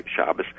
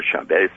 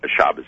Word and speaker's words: Schabas: And Goldstone has criticized the guy Schabas who, uh, or Schabas, whatever uh, different pronunciations of his name. He's not Jewish. It's not Shabbos Shabbos.